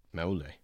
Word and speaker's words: Maule: You probably pronounce the E at the end, right? – Yeah, it's Spanish. It's not Polynesian. – Maule.